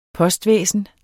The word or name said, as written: postvæsen